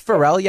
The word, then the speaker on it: Pharrell: It's Pharrell.